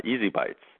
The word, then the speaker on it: ESI Bites: You can also remember the show and website phonetically, Easy Bites. That's the way it sounds out of ESI information.